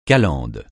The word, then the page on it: calendes: francais